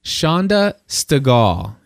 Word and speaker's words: Chanda Stegall: And so today we are very glad to have on the phone with us right now Chanda Stegall.